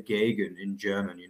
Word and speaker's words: Gegen: The correct way to say it is Gegen, like Gegen pressing.